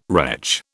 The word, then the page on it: retch: British English